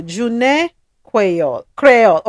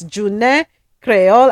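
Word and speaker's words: Jounen Kwéyòl: So let me spell it. June. Thank you. June Creole. Okay, so it's June Creole, and I'm going to spell it J-O-U-N-E-N. K-W-E-Y-O-L. So that's a celebration that happens in Saint Lucia.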